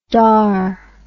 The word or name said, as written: daur